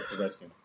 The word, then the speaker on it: Povetkin: Those two names are probably the names that you'll hear over the next few months, specifically Alexander Povetkin. I hope I pronounced his last name. – You're right, Povetkin. That's what it is.